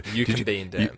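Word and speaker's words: Eucumbene Dam: Eucombine Dam. That's how you pronounce it.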